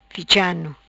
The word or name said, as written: Ficha Ano